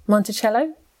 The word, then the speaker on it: Monticello: So Lizzie was charged with the murders and held for trial at the Sullivan County Jail in Monticello, New York. I've said that right, yeah? Monticello? – Yeah. Nailed it.